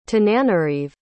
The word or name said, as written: tananarive